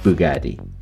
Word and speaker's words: Bugatti: which was called later Ettore Bugatti, which the stress is on G, but it is written with a single G and double T.